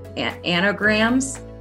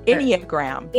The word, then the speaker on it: Enneagram: anagrams? – Enneagram.